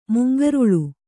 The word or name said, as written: ಮುಂಗೊರಲ್